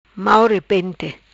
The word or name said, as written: Mau repente